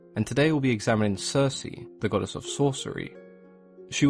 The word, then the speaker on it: Circe: when they were Latinized and Anglicized, the hard K sound became a soft C, and we now refer to her as Circe.